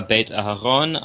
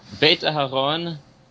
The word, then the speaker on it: Beit Aharon: My name is Noah Beit Aharon. I publish under the name N.S. Dolkart. – Beit Aharon for those. – Beit Aharon in English is spelled B-E-I-T hyphen A-H-A-R-O-N.